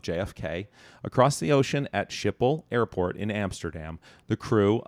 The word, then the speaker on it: Schiphol: Schiphol.